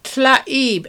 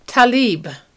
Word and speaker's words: Tlaib: Our friend, Rashida Tlaib, and by the way, a lot of people, most people, call her Talib. That's not it. If you look at the way her name is spelled, it's spelled T-L-A-I-B. We don't have a word in English that has those letters in that combination. It's Arabic. And the name is Tlaib.